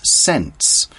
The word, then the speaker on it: scent: Now, Charlie, the article says, Charlie loved chocolate and often stood outside the gates smelling the delicious scents. Okay, scent is spelled S-C-E-N-T. The C is a silent letter.